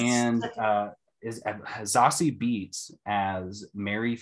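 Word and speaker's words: Zazie: Z-A, like she said to pronounce it as Z-A-H-S-E-E, Zossie.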